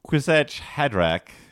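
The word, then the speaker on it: Kwisatz Haderach: When trying to get my partner into it, they turned me down, often shuddering at words like Quisetch Hadrach. – You pronounced it totally right.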